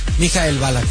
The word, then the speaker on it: Michael Ballack: ¿Cómo se dice bien? ¿Michael Ballack? – Michael Ballack, sí.